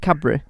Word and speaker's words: Cadbury: I think everyone says it right, Cadbury. You don't pronounce the D, so people like Cadbury, you don't do that, it's like Cadbury. – That's it. That's exactly how it should sound.